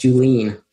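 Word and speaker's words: Julien: It's J-U-L-I-E-N, but it's pronounced Juline. Like, don't Juline on the table.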